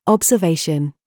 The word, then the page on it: BOB: British English